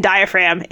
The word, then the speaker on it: diaphragm: Oh, no, the silent G was in diaphragm in this case.